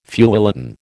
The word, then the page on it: feuilleton: American English